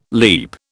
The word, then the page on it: leap: British English